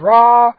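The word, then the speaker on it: ra (רע): The Hebrew word ra, or ra, however you want to pronounce it, both are acceptable in the lexicons, and has a variety of translations, depending on the context.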